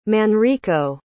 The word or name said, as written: Manrico